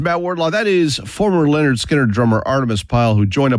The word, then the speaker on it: Lynyrd Skynyrd: But tonight, we are listening to the very first Skynyrd album, pronounced Lynyrd Skynyrd.